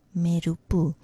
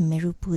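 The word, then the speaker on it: meru-poo (మెరుపు): So you can make it more smooth and say meru-poo. It's not rushed. Each syllable is specific and very clear. Then you get the pronunciation meru-poo. So what does meru-poo then mean?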